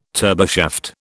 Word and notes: turboshaft: British English

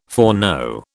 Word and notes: foreknow: British English

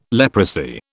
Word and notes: leprosy: British English

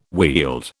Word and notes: wield: British English

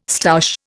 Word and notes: stoush: Indian

